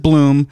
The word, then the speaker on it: Blomberg: You pronounce it Bloom, but if you want to not confuse yourself, it's Blom, right? – Just say it in your head that way, but Ron don't say Blom.